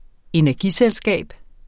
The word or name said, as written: energiselskab